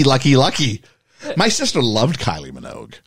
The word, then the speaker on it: Kylie Minogue: Kylie Minogue, am I saying it right? – Yes. – Oh, okay. – Yes, Kylie Minogue.